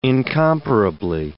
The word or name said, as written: incomparably